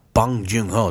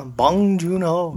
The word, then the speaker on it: Bong Joon-ho: Snowpiercer is a film by Bong Joon-ho. Did I say that right? – Uh-huh. Bong Joon-ho. – Bong Joon-ho.